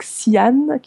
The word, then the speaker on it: Xi'an: Et donc Xi'an, qui s'écrit X-I-A-N, qu'on prononce parfois Xi'an,